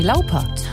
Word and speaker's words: Lauper: Eigentlich ist sie aber eine Cindy Lauper. Der Papa, Fred, Schweiz bzw. deutschstämmig. Das ist eigentlich, würde es deutsch ausgesprochen werden.